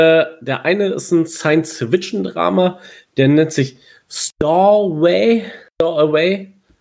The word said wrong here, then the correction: Stowaway: Habe ich das aus richtig ausgesprochen, Kalle? Ah, nee, du machst ja kein Englisch, ne? – Doch, doch. Aber so wie ich das sehe, ist es Stowaway wahrscheinlich.